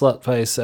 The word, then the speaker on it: Slutface: It is spelt Slotfach, but pronounced Slutface, and they're very insistent that that's how it's said.